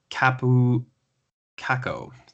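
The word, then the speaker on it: Kaapo Kakko: Let's talk about draft eligible forward Kapu Kakko. Am I saying that right? – Yeah, pretty much. Kapu, yeah? Kakko. That's right. How we say it.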